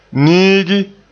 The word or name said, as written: niigi